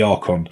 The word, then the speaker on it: Archon: A-R-C-H-O-N.